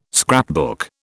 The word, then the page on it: scrapbook: British English